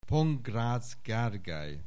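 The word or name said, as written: Pongrácz Gergely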